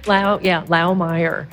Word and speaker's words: Laumeier: No, it is Laumeier. It's like I said, is the name of the people who left the house in poverty, and they were German. It's a German name.